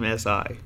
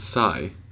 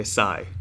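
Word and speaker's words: Si: Who's team S.I.? From Duck Dynasty. – It's Psy. – Okay, Psy. – Is it? What do you mean, S.I.? – Yeah, it's just Psy. I don't want to say it wrong.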